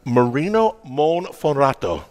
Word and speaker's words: Marino Monferrato: Marino Monforato.